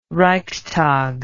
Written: reichstag

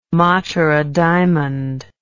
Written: matura diamond

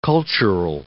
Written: cultural

